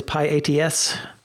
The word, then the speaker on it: pyATS: It is pyATS.